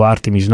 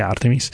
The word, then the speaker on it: Artemis: E in generale l'architettura Artemis, io mi leggo alla pronuncia americana perché vivo a Houston, sono abituato a pronunciarla in questo modo, dico Artemis non perché lo preferisca, solo perché sono abituato a sentirlo pronunciare Artemis.